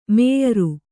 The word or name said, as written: ಮೇಯರು